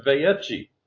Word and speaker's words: Vayechi: Okay, so this Torah portion is called, I'm pronouncing it Vayechi. That looks to me like an Italian pronunciation, Vayechi, kind of like Malachi instead of Malachi.